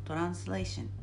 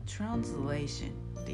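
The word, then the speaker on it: translation: ご紹介していきますどうぞよろしくお願いしますちなみにこのポッドキャストのタイトル「ロスト・イン・トランスレーション」の「トランスレーション」という言葉これ英語だと S が Z の発音になるんですよだから、えー、きちんと発音するとトランスレーショントランスレーションっていう風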